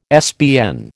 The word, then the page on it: SBN: American English